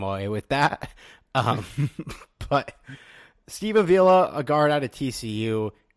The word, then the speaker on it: Avila: Did I say that right? Do you know how to say it? – Avila. – Avila. Okay.